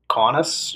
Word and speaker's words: Kaunas: But, so upon entering Kaunas, I believe that's how you say it, Kaunas? – Kaunas, yeah, sounds right, Lithuania.